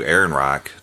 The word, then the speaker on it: Ehrenreich: Ehrenreich. Did I say his name right? – I think so.